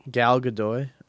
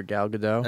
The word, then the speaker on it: Gal Gadot: Gal Gadot or Gal Gadot? – That's how you say her name.